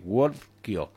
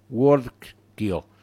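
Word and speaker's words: Whordkio: WorkDog, acuérdense, WorkDog, se escribe W-H-O-R-D-K-I-O, W-H-O-R-D-K-I-O, WorkDog.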